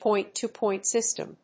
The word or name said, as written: point-to-point system